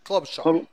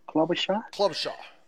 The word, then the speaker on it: Klobuchar: ¿Cómo, dijiste que se pronuncia? Klobuchar. – Klobuchar.